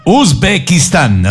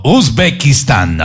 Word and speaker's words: Uzbekistán: Uzbekistán, así es, ¿no? Uzbekistán, así es, ya.